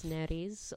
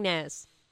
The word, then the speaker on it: nares: Argue on the pronunciation of that, where it's Neres or Nares, but I'm saying Nares. It's Nares.